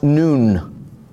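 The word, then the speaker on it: nun: It is pronounced noon.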